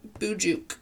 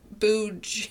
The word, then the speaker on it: Bujok: Boojuke. Booj. It's spelled Book. B-U-J-O-K. – Okay. – Oh, what did I say? – No. – B-U-J-O-K.